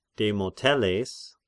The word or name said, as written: Demoteles